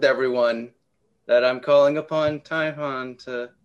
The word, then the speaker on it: Typhon: Like, it's Typhon, and yes, that's how you say his name, the deity? – Typhon.